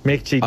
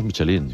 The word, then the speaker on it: Michelin: Oye, Michelin, ¿cómo se pronuncia definitivamente? – Michelin. A ver, aquí en la península ibérica hacemos Michelin.